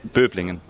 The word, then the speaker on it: Böblingen: Böblingen. – Yeah, it's quite close. – Yeah. – How do you say? – Böblingen.